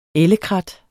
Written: ellekrat

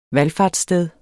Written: valfartssted

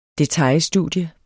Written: detailstudie

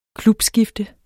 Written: klubskifte

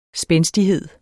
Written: spændstighed

